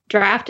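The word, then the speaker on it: draught: Like, well, but it is pronounced draft, whether it's spelled the long way or the short way.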